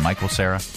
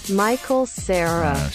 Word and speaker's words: Michael Cera: That's Michael Cera, right? – Michael Cera. – That's how you pronounce his name. Michael Cera. – Michael Cera.